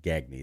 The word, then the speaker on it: Gagne: Her name's Jacqueline Gage. – Okay. – Or Jacqueline Gagne is actually how it's pronounced. It's okay. – G-A-G-N-E.